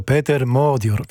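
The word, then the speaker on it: Péter Magyar: I zapytałem Michała, jak się poprawnie wymawia imię i nazwisko węgierskiego premiera, który właśnie jest w Polsce. Michał powiedział Peter Modior, więc tak dzisiaj będziemy o nim mówili.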